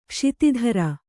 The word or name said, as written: ಕ್ಷಿತಿಧರ